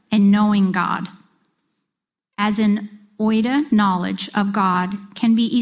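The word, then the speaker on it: oida (οἶδα): Okay, now there's another Greek word for the word know, and it's pronounced oida, and it's spelled O-I-D-A.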